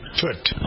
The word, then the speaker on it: Tut: You pronounce it Tut? How do you pronounce it? Tut. – I say Tut.